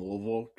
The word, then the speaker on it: Louisville: Well, I am born and raised in Louisville, Kentucky. That's how you say it. If you if you say more than like one syllable, you've said it wrong.